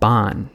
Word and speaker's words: Baan: And another was a company from the Netherlands called Baan, that's spelled B-A-A-N.